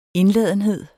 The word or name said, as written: INDLADENHED